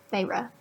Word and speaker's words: Feyre: Probably Feyre. Right? That's how you pronounce it? – Yes. – Yeah. Feyre.